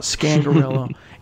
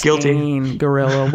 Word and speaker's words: Scangarello: It's not Scangarello. It's Scangarello, which both of us were. You know, I didn't know that until I read the press release. But anyway, so the Broncos initially request Scangarello.